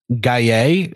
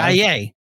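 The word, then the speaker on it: Gueye: So how do you pronounce his last name? – Gaye is what Gaye. Okay. – That's what I've heard on a broadcast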